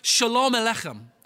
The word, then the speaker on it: Shalom Aleichem: In Hebrew, it's pronounced Shalom Aleichem.